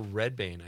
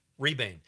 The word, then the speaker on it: Rebane: Mostly about the director, Bill Redbane. I think That's how you say it. Rebane.